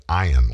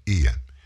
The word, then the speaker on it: Ian: You remember going back down to Florida and Hurricane Ian, it's hard for me to start the pronunciation with E when there's an I there. It should be Ian, like Ivan, but it's not. Hurricane Ian.